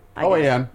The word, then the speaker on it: OAN: The right wing, how do you say it? OAN? – OAN. – OAN. – Yeah, OAN.